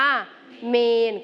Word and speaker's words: เมรุ: บางคนอาจจะอ่านว่าเมรุหรือเมรุใช่ไหมคะแต่จริงๆคำนี้ต้องอ่านว่าเมน